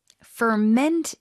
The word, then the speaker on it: ferment: 자, 발효시키다 동사 뒤에 강세 다시 한번 드릴게요. – Ferment it. – Ferment it. – Ferment it. – 네, 뒤에 힘 잘 주시고.